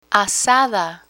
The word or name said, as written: azada